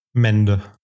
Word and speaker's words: Mende: Mende.